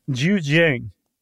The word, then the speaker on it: Zhejiang: Zhejiang. – The Zhejiang province in China, where in the early 90s, in 1992 specifically, the people that lived in the villages near these ponds.